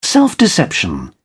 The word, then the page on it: self-deception: British English